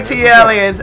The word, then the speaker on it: ATLians: I like my ATLings. Am I saying that right? – Got it. – Is it ATL? No, I think I'm saying it wrong. But it, it, no, you're saying it right.